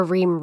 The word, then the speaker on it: Reaume: Well, my Instagram handle, I think, is Amber Ream Realtor. I will spell my last name because no one knows how to spell it. Amber, A-M-B-E-R, Ream, R-E-A-U-M-E, Realtor, R-E-A-L-T-O-R.